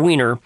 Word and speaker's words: Weiner: Because I think we for so long now have had Mr. Weiner. And yes, that is how you pronounce his name.